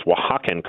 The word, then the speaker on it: Oaxacan: I've been working the whole day to try to pronounce Oaxacan correctly.